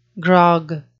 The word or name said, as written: grog